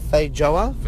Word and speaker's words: feijoa: I'm going to try and say a feijoa. F-E-I-J-O-A. Feijoa? Feijoa? – Feijoa, yeah.